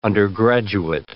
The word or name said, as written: undergraduate